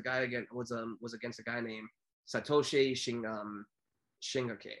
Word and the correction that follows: Shingaki: I'm totally butchering his name here. – Yeah, Satoshi Shingaki. – Shingaki, totally.